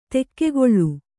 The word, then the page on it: ದೊಗ್ಗಳೆ: Kannada